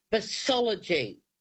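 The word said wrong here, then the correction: psephology: Say it again. Go on. – PSE. – Yeah, okay, good. Good. That's the one. It's and it's pronounced cephology.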